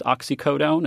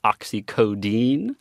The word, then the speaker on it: oxycodone: And so former employees of the company also told me, you know, we had this other advantage, which is that a lot of people mispronounced oxycodone and they called it oxycodine.